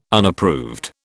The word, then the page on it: Unapproved: British English